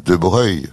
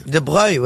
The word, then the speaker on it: Broglie: Bah, je comprends, vous dites de Broglie, alors donc. – Oui. – Abruti. – Laurent, c'est pas vrai parce que vous. – Normalement, dites... les gens de famille disent De Breuil. – De Breuil, oui.